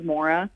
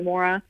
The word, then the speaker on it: Maura: And that's why I say the actual pronunciation of my name is Maura. – Okay. – And my Irish friends and family, or my Irish and Scottish friends and family call me Maura. So I probably should go back to that because I know that I'm hurting their heart every time I pronounce it incorrectly.